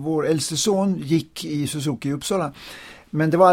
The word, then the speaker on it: Suzuki: Eller uttalas det så? – Ja, Suzuki. Ja, Shinichi. ja, ja. det är rätt.